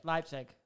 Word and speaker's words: Leipzig: Is it Leipzig? That's how you say it. Leipzig.